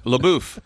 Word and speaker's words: LaBeouf: How do you pronounce his last name? – LaBeouf. – Maybe it's LaBeouf.